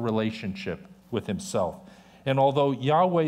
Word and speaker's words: Yahweh: For many years, scholars believed the name should be pronounced Jehovah, modern scholars believe it should be pronounced Yahweh.